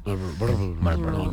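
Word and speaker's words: Marlboro: Oye, sí, es que una R es que nunca suena. – No se pronuncia. – ¿No se pronuncia? – Malboro. Nadie dice Malboro.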